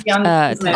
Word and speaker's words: Tencel: I think MeUndies is made of Tencel.